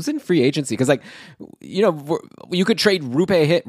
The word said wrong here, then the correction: Roope Hintz: Roope Hintz. I guess I should say his name right.